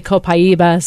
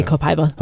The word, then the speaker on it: Copaiba: And I was wondering, and it seems to me like there's a new thing that I've never heard of called Kabiba. Hey, am I saying that right? – Yeah. Kabiba. Or some people say Copaiba, some say Copaiba.